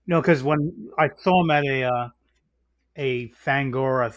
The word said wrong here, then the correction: Fangoria: thing, and they were pronouncing it as Ungland. – Fangoria, you mean? – Yeah. – I don't know if I can trust your pronunciations if you're mispronouncing the thing that you're... – What did I say? I said Fangoria. – You said Fangora.